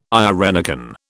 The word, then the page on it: irenicon: British English